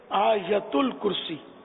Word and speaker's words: آیت الکرسی: یہ آیت الکرسی عوام بولتے ہیں، صحیح تلفظ ہے آیت الکرسی.